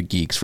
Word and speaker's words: Guix: Shout out to Carl Dong and everybody who contributed to the Geek's reproducible builds for Bitcoin Core 2. – Which, by the way, is spelled Gwix. – G-U-I-X. – But it's pronounced Geeks.